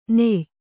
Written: ನಯ

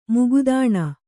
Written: ಮುಗದಾಣ

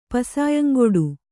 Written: ಪಸಾಯಂಗೊಡು

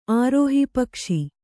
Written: ಆರೋಹಿಪಕ್ಷಿ

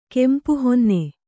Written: ಕೆಂಪುಹೊನ್ನೆ

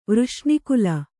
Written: ವೃಷ್ಣಿಕುಲ

